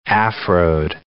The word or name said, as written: Afroed